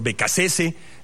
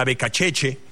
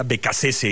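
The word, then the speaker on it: Beccacece: Es de origen italiano. Si fuera él italiano, sería Becacheche. Pero pues los argentinos no suelen pronunciar sus apellidos como originalmente fueron de sus antepasados. Yo me metí a checar antes de que empezara la Copa del Mundo cómo se pronuncia, y se pronuncia como creía, Becasese.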